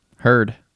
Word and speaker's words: Hurd: Brad, what's your last name? Hurd. – You heard? Hurd. – like, oh wait, how do you spell that? H U R D.